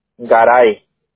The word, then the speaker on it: Garay: Garay. – ¿Cómo se escribe? – G-A-R-A-Y.